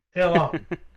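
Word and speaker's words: Illang: Ilang is how I even pulled up like the Google, like, hey, pronounce this for me. Ilang. Sounds great.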